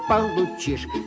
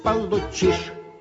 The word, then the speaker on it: получишь: И если верно сократишь, то пятерку получишь. Если верно сократишь, то пятерку получишь. – Николай Владимирович, нельзя говорить «получишь». Надо говорить «получишь». Вы неправильное ударение ставите.